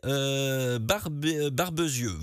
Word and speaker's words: Barbezieux: Emmanuel qui me dit que j'ai bien prononcé Barbezieux.